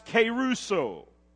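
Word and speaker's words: keruso (κηρύσσω): That word preach is a Greek word we pronounce keruso.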